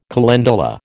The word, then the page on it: calendula: American English